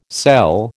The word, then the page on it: sell: American English